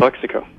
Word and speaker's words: Calexico: Calexico.